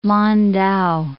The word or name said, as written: Lan Tau